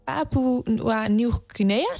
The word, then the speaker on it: Papua Nieuw-Guinea: Toen gingen we naar Papua Nieuw-Guinea, spreek je dat goed uit? – Ja.